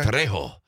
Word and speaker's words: Trejo: I don't think you pronounce the R. I think it's Tre. – Okay. – Trejo. Danny Trejo. – Well, you are pronouncing the R a little bit.